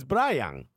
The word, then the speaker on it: Brayan: Brian is how you say Brian in Spanish, but he spells it, or his mom spelled it, Brayan.